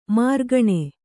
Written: ಮೆರುಗೆಣ್ಣೆ

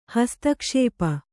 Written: ಹಸ್ತಕ್ಷೇಪ

